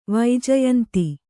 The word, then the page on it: ವೈಜಯಂತಿ: vaijayanti